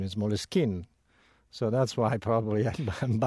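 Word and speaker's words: Moleskine: Having said that, though, Moleskin as a word is originally an English word. So the English pronunciation is Moleskin.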